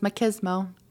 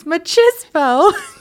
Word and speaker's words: machismo: And my fiance was like, no, it's like machismo. It's machismo because it's like macho.